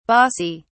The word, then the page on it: bassi: British English